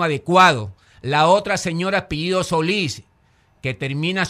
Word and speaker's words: Solís: Así como está escrito, se pronuncia Solís. – Claro. – Pues Solís es S-O-L-I, claro. Tilde S. – Ya, ella dice Solís, debería ser aguda. – Solís. – Debería. – Es una palabra aguda.